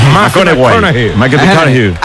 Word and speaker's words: McConaughey: ¿Mateo McConaughey? – Sí. – Hay que ir corrigiendo la pronunciación. – McConaughey.